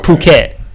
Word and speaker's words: Phuket: Like, the PH sound here has the F sound, but there, the H is basically silent. So if you see that PH, whatever the word is, you pronounce it as if the H isn't there. Okay. So look at it as like P-U-K-E-T, okay. Phuket. So like that's how, there's another one that's like P-H-I-P-H-I.